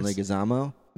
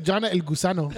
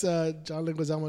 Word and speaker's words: Leguizamo: How do you say his last name? Say it. – John Leguizamo. – Leguizamo. Leguizamo. Okay. I'm sorry. I could not say it right. Leguizamo. – He sounded, I thought he said John El Gusano. – John Hosana. – Like. No, it's John Leguizamo.